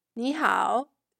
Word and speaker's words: 你好: 你好 Hello in Mandarin. You'll learn how to say hello in formal and informal way. 你好, which literally means you, good.